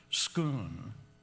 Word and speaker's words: Scone: And so when we come out, I say, that's known as the Stone of Scone, or some pronounce it Scoon.